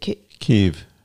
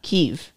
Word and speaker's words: Kyiv: Putin, he alleged, how do you say the very famous place in Ukraine that's been invaded? – Kyiv. – Kyiv. Uh-huh. It's K-Y-I-V.